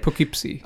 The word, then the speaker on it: Poughkeepsie: I don't know how you say it. – Poughkeepsie.